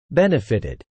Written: benefitted

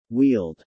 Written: wheeled

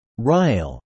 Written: rile

